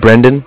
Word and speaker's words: Brendan: Brendan. It's spelled B-R-E-N-D-A-N. Brendan. What's your name? – Fetterman. F like Frank. E-D-E-R-M-A-N.